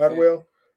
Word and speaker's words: Atwell: Is that how you say it? Atwell. – Atwell uh, is Peggy Carter, which she also had her own little spinoff show.